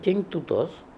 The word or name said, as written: King Too Toz